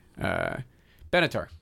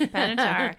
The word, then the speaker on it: Benatar: And I've seen it pronounced like Andrzejewski, Andrzejewski, Benatar. Good work. – Benatar.